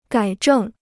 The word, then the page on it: 改正: gǎi zhèng